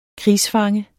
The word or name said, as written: krigsfange